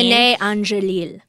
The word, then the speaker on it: René Angélil: René Angélil.